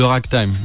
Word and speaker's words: ragtime: C'est le ragtime, pour prononcer à l'anglais.